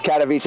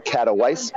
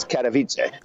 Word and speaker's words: Katowice: Yes, Katowice. It looks like Katowice, but it's Katowice.